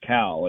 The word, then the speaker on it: Khal: It's spelled K-H-A-L, but it's pronounced Cal.